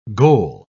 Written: goal

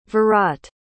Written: virat

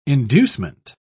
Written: inducement

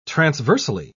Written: transversely

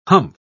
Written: humph

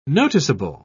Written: noticeable

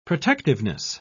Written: protectiveness

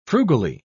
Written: frugally

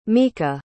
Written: mika

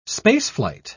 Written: spaceflight

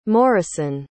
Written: morrison

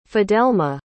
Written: fidelma

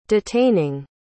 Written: detaining

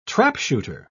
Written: trapshooter

